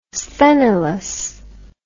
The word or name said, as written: Sthenelus